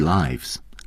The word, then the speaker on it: lives: I've been sharing the daily lives，大家注意发音啊，是 life 的复数形式。